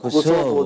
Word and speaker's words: كوسوفو: فإدرنا هذه هي العاصمة، خاض هذه المعارك واستشهد هنا في معركة قصوه، اللي هي يعني إيه كوسوفو، تنطق كوسوفو دلوقتي.